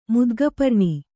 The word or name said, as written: ಮುದ್ಗಪರ್ಣಿ